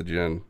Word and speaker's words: djinn: The D is silent, just like Connery's djinn, okay.